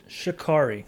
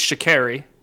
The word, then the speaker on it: Sha'Carri: Sha'Carri. It's Sha'Carri. Literally, her handle on Twitter is It's Carrie. So it's Sha'Carri Richardson. – My apologies. Sha'Carri.